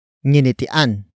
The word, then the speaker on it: nginitian: The first word is nginitian. Nginitian. Nginitian. Nginitian is spelled as ng, I, n, I, t, I, a, n.